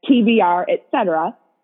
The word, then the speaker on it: TBR, etc.: But if you were to look me up on Instagram, it kind of looks like a weird word, like tuberec or something, but it's actually supposed to be pronounced as TBR, etc.